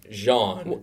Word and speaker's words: Jean: Was it Gene? Was it Jean? It depends which radio station you ask. – Well, the radio station in question wanted to be authentic and pronounce it Jean.